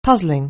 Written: puzzling

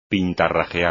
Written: pintarrajear

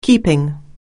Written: keeping

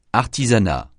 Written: artisanat

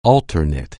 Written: alter ego

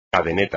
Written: cadeneta

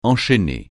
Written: enchaîner

